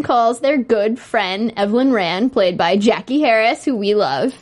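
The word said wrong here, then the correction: Jackée Harry: But isn't it Jackie Harry? See, I thought we were always calling her Jackie Harris, and it's Jackie Harry.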